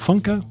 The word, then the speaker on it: Funko: Is that how you say it, Funko? – Yes, and other collectible toys.